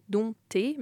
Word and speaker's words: dompté: Par exemple, dompté, qui se disait dompté, maintenant on prononce presque le P.